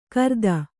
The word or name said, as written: ಘೃತ